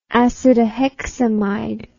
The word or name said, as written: acetohexamide